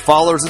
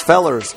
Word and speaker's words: Rockefellers: Oh yeah, that's right, it's not pronounced fallers, it's fellers.